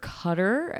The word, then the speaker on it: Qatar: But I mean, going to live in Qatar, or Qatar, as we pronounce it sometimes.